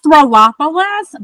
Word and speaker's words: Throlopolis: Throlopolis, but a lot of people also just kind of nickname me Corporate Erin.